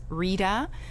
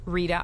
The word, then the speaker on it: Rita: All my social media is my name, which is Rita Verreos. Hard to forget Rita, like margarita, but not margarita, not the one you drink. It's just Rita, R-I-T-A, and Verreos.